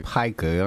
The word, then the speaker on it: Pika: There, Pika.